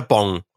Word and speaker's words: Bon: Nee, Bonpflicht oder Bong? Ja, aber das ist doch B-O-N nur.